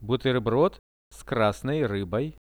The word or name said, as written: buterbrod s krasnoi ryboi